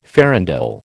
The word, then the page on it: farandole: American English